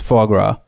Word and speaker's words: foie gras: Well, the swan spitting in a salad could actually alleviate a problem we have in the world, which is foie gras.